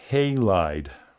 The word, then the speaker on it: Halide: And the way that he says it's pronounced, and I think he is absolutely correct, is Halide. Halide is the app, and I was not saying it that way.